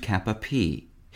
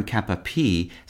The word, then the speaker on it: cap-a-pie: Knights thus outfitted were said to be armed kap-uh-PEE. The term kap-uh-PEE,